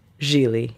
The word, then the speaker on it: Gigli: The movie is called Gigli. Rhymes with really.